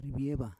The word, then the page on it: rivieva: Roon